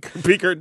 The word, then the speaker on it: Peaker: Peaker Bitter Energy. Bitter. I think Peaker rhymes with tweaker.